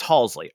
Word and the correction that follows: Halsey: I, I mispronounced Halsey, yeah.